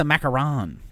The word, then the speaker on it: macaron: No, that's a macaron. The macaroon is the coconut one.